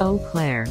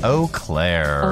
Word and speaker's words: Eau Claire: Eau Claire. – Eau Claire. – Eau Claire. – Eau Claire. – Eau Claire. – Eau Claire. – Eau Claire. – Eau Claire. Anyway, take two. The Eau Claire Police Department posted about it on Facebook and called it the most Wisconsin complaint they've ever received.